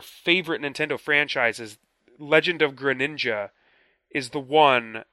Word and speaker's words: Greninja: And then the only, I think, truly new character is Greninja? Did I pronounce that correctly? Greninja? – Yeah, Greninja.